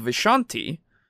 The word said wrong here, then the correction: Vistani: or, sorry, I don't think that's... – Vistani, thank you. I don't know why I keep pronouncing it that way, but... – It's how we pronounced it in our campaign. – I know, yeah, and I never pronounced it right.